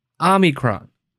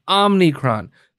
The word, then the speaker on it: Omicron: It's Omicron, Omicron. That is the actual way to pronounce it. Omicron. O M I C R O N. Omicron. It's not Omnicron. There's no extra N Brought to you by the person who believes that we should all take a booster.